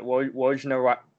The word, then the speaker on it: Wojnarowski: So, essentially, the way Adrian Wojnarowski, what is it? – Wojnarowski.